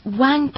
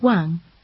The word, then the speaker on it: Wang: It's Wang Pearl. – Excuse me? Is Pearl your last name? – No, it's my first name. My family name is Wang. – Can you spell that? – Sure. Pearl, P-E-A-R-L. Wang is spelled W-A-N-G.